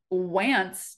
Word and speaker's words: Wantz: So when Andrew Wance, W A N T Z, was warming up in the bullpen, I thought the entire time that it was Wants. Just, I kind of just took it for granted. – Yeah. I didn't even think that there might be an alternate pronunciation there.